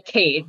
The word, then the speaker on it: Khaide: That's ARIA KHAIDE, and it's Aria Cade. That's how you pronounce that.